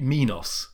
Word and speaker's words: Minos: But I would not have pronounced it Minos, which people often do, and it drives me up the wall. Surely it was the Minotaur, King Minos, not Minos. You don't say Minotaur.